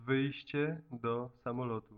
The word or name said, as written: wyjscie do samolotu